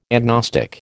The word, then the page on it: agnostic: American English